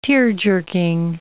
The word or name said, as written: tear-jerking